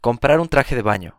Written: comprar un traje de baño

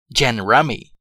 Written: gin rummy

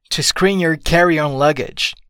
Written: to screen your carry on luggage

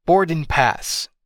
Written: boarding pass

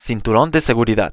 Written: cinturon de seguridad

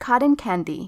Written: cotton candy

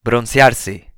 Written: broncearse

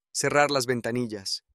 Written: cerrar las ventanillas

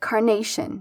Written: carnation

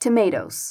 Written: tomatoes

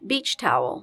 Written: beach towel

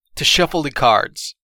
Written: to shuffle the cards

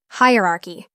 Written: hierarchy

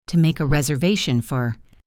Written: to make a reservation for…

